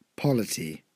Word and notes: polity: British English

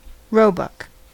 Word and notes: roebuck: American English

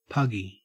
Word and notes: puggy: Australian English

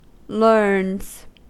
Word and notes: learns: American English

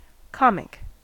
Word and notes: comic: American English

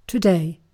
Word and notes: today: British English

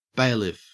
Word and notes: bailiff: Australian English

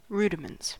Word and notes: rudiments: American English

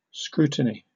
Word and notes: scrutiny: British English

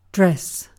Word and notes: dress: British English

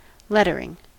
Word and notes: lettering: American English

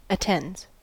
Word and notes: attends: American English

